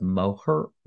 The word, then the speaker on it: Moher: and over by the cliffs of, and I would have said cliffs of more, but I guess they pronounce it Moher, Mo, Moher, more, Moher, Moher, Moher.